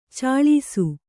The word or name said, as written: ಛಳಿಸು